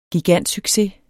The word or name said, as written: gigantsucces